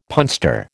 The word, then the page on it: punster: American English